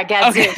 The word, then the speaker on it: Gadzooks: We didn't have Gadzooks in New York, but Gadzooks? – Am I saying that right? Yeah, Gadzooks. Okay.